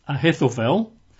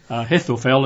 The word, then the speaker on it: Ahithophel: Her grandfather, Eliam's dad, is a man called Ahithophel. I'll spell that, but you'll find it in some scriptures. A-H-I-T-H-O-P-H-E-L. Okay. Ahithophel, it's not an easy one to say, I've got to say. But Ahithophel, so A-H-I-T-H-O-P-H-E-L.